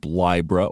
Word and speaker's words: Libra: And we scroll down to Libra, and it is pronounced Libra, I as in bind or time.